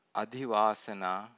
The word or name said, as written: adhivasana